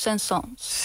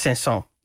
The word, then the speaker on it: Saint-Saëns: Het is van de Franse componist Camille Saint-Saëns. Zeg ik dat goed? – Nee, je spreekt het uit als Saint-Saëns. – Saint-Saëns.